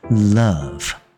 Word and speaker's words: love: 我们也不是念 love love，我们也不是这个爱的英文，我们是念 U 的短母音呃的，所以它念 love 啊、哦，大家要小心哦，不要念成 love love，它嘴巴小一点啊、哦，是比较自然的，张大大，然后念呃，OK 呃，所以是 love，我爱你。